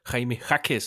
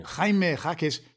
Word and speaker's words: Jaime Jaquez: Du siger det rigtig godt. – Nå, jeg er blevet bedre. – Du glemmer bare lige at sige junior. – Junior. – Jaime Jaquez junior.